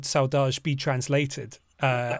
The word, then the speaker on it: saudade: Well, I as I read it as as I pronounced it when I read it, uh, saudade, though my Brazilian friend told me it's saudade, uh, is how it should be pronounced.